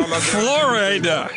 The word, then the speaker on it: Flo Rida: Flo Rida.